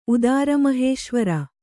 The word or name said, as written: ಉದಾರಮಹೇಶ್ವರ